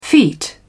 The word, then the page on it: feet: British English